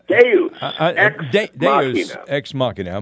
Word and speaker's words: deus ex machina: I'm hoping for do ex machina. Is that what you said? – Deus ex machina.